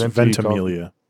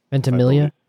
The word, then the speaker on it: Ventimiglia: With Anthony Hopkins and Milo, Ventimiglia. – Ventimiglia. – I believe it's pronounced Ventimiglia. – Ventimiglia.